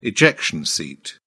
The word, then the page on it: ejection seat: British English